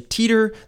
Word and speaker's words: Titor: There's a lot of different ways that people pronounce his name. And because his name is on the internet and was rarely spoken, people say Teeter, they say Titor, I've heard Titor.